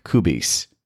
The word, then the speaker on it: Kubis: So the first ever position of a flight attendant belonged to a German man named Heinrich Kubis. K-U-B-I-S. Am I pronouncing that okay? – Kubis, yeah. – Kubis.